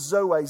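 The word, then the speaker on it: Zoe (ζωή): The Greek word is zoe, Z-O-E. It's pronounced Z-O-A. Zoe.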